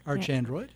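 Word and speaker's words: ArchAndroid: Say, how do you say the record? – Arch Android. – The Arch Android record.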